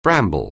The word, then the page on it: bramble: anglais